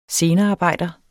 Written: scenearbejder

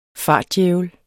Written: fartdjævel

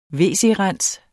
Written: wc-rens

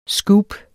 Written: SCOOPET

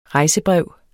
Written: rejsebrev

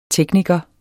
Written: tekniker

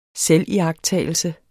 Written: selviagttagelse

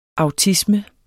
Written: autisme

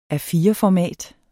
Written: A4-format

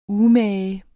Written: umage

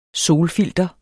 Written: SOLFILTER

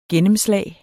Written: gennemslag

